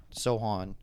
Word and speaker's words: Sochan: So I don't think it's Sochan. Sochan. I think this C is silent, they were saying last night.